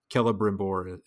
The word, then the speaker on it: Celebrimbor: The C's in Sylvan languages, or rather Elven languages, are generally hard C's. So Celebrimbor is a hard C, Círdan is a hard C, and in most cases it's going to be hard C.